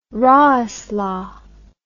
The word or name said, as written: roesler